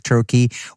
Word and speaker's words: troche: A trochee is just actually a mechanism of a delivery, right? It's spelled T-R-O-C-H-E. So for those who are listening, if you see that word, you can pronounce it correctly.